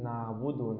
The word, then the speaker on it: نعبد: فالصواب الله أعلم أن تقول نعبد نعبد نعبد.